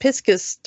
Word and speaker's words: Pisces: According to the Oxford Dictionary, apparently Piscus is a acceptable secondary pronunciation for Pisces.